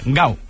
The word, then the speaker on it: Gal: sino Gal, que en portugués de Brasil se pronuncia igual, Gau.